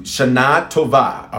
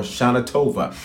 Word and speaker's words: Shana Tova: Shana Tova. All right. That's the appropriate pronunciation, or Shana Tova. We are in a new spiritual year. That's the Hebrew way to say you have a good year.